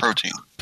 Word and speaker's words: protein: Is it protein or protean? For like, protein. – For the planks. Protein. All right.